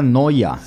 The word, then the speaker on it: Neuer: El arquero alemán, por ejemplo, se escribe Neuer. Neuer, pero pronuncia Noya.